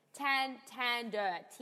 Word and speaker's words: Tander: Wait, because Tanda or Tander? – Tander, T-A-N-D-E-R. Okay. – You'd think now, I would be the one pronouncing that wrong, but no. Tanda. Well, Tanda. Yeah. Tanda. – I'm just saying, with a Boston accent.